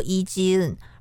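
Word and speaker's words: 伊 基 恩 (이지은): 真正有趣的是，在其实 IU 他的本名叫做李智恩，韩文的发音呢就念作伊基恩，而那段歌词正是把他的姓往后放，而转成了作者的意思。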